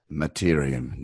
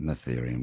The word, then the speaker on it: Mattereum: I've been saying it wrong the whole time. It's Mattereum, not Mattereum.